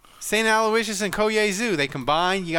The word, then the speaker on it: Aloysius: Oh, that's Aloysius.